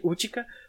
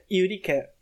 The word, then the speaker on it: Utica: Eu vou chamar ela de Utica, por mais que a pronúncia certa seja Yurika, porque eu não vou conseguir ficar falando Yurika sem, sem ficar revirando os olhos para mim mesmo.